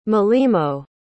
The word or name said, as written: molimo